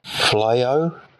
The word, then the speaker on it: Fleaux: Talking of pronunciation, I've been listening to the Weekly Scroll TTRPG podcast, where they reviewed Kubayashi's Flao. This is spelled F-L-E-A-U-X. That's pronounced Flao, which makes sense because I believe Kubayashi is French.